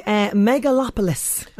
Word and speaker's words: Megalopolis: Okay, Megalopolis.